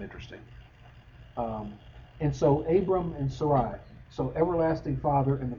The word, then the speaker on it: Sarai: Or Sarai, I guess, is the way you pronounce her name at this moment.